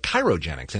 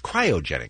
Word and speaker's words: cryogenics: I always want to call it chirogenics, and it's not that at all. It's cryogenics, mm-hmm.